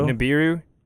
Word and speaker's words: Nibiru: It was Nibiru, is how you say it? – Nibiru.